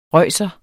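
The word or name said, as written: RØJSEREN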